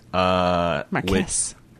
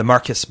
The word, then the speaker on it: Marquis: Marcus? – Oh, I thought, okay. Well, I was pronouncing it with the French pronunciation. The Marquis Blaine.